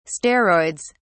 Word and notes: steroids: British English

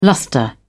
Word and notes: lustre: British English

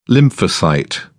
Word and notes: lymphocyte: British English

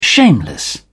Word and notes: shameless: British English

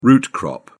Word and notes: root-crop: British English

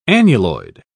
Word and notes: annuloid: British English

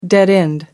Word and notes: dead-end: American English

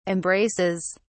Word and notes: embraces: British English

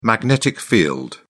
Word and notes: magnetic field: British English

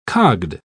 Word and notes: cogged: British English

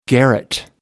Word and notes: garret: American English

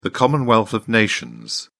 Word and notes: commonwealth of nations: British English

